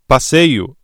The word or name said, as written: passeio